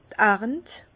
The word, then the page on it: d' Arnt: Bas Rhin; Herrlisheim